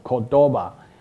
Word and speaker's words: Cordova: 那我们今天要谈的这个主题非常的重要，就是深入 Apache Cordova 的开发。当然我们第一个要学的就是说，其实我们现在要了解如何去做跨平台，那我们用的这个方式就是透过 Cordova。那我们大家知道这个字怎么念哦， Cordova，那不是 Wakamado 啊， Wakamado 呢是键位整场，那 Cordova 是跨平台。